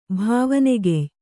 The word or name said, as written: ಭಾವನೆಗೆಯ್